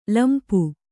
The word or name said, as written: ಲಂಪು